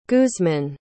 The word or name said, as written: guzman